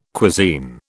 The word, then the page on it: cuisine: British English